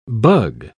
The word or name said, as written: bug